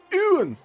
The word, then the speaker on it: Ewan: Por ejemplo, no se dice Ewan McGregor, ni siquiera se dice Ewan McGregor. – ¿No? – No. Resulta que es un nombre escocés y se dice, atención, Ewan.